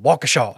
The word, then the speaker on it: Waukesha: That's just Waukesha.